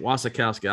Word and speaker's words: Wasikowska: Mia Wasikowska. Yeah. That's it. Wasikowska. I'm saying it right. – And Jessica Chastain. – Everybody else is saying it wrong.